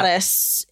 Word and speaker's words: Ottis: It's O-T-T-I-S, right? – Yeah. – Ottis is, I guess, how it's actually pronounced. Mm-hmm. I tried to make the same correction on Saturday when we were recording. – Yeah, it is pronounced Ottis Toole from what the book that I read.